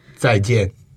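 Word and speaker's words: zài jiàn: Zai jian. Yeah, the similar like in my name. – Oh. – The jian. Pronunciation is the same, but different character.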